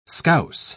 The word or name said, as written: scouse